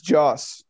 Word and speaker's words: Joss: He said Josh. It's Joss. – Yeah, it's Joss.